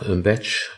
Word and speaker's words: Bach: Also Bach selber, Batch, hat sich für die Batch-Aussprache mal entschieden, obwohl das gar nicht so klar war. Die Familie kommt aus Wales und dort spricht man tatsächlich den Namen Bach aus.